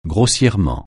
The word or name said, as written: grossièrement